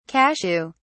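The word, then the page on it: casserole: British English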